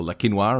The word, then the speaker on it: Le Quesnoy: I mean, look back to our episode on Le Quesnoy, or Le Quinoir, as we know it's pronounced.